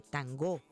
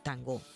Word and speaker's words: tangó: En épocas de la colonia, los africanos recién llegados llamaban a sus tambores con el nombre de tangó, con acento en la segunda sílaba.